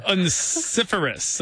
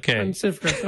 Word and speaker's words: Onesiphorus: Onesiphorus, I would say. – Onesiphorus, okay.